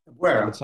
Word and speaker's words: Albuera: At the Battle of, now I'm going to butcher this, so correct me, Albuera. – Albuera, that's right.